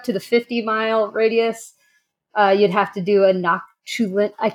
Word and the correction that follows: Noctilucent: Noctilucent clouds.